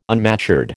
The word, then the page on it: unmatured: American English